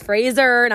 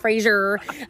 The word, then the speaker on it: Fraser: That is how you say it, Americans, by the way. Okay, we've been saying it wrong. It's Fraser, not Fraser.